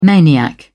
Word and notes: maniac: British English

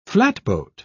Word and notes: flatboat: British English

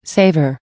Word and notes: savor: American English

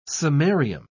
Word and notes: samarium: British English

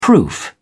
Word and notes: proof: British English

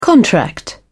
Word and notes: contract: British English

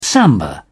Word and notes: samba: British English

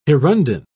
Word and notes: hirundine: British English

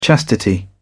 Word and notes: chastity: British English

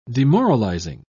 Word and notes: demoralising: British English

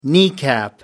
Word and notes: kneecap: American English

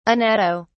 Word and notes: anatto: British English

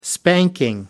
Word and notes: spanking: American English

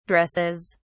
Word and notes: dresses: British English

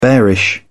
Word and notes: bearish: British English

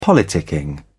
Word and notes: politicking: British English

